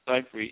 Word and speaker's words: Seifried: Seifried, you got it.